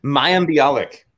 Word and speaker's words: Mayim Bialik: MAYIM Bialik.